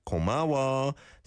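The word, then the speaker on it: Komawa (고마워): Sounded at the end like komawa, but it's komawa, an O vowel sound to an A vowel sound.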